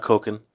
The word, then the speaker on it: Cokin: Actually, would you mind telling me a little bit about Kokin? – Kokin, which is spelled C-O-K-I-N, is actually a mutation of Jean Coquin, who was a French photographer, and he decided that, you know something?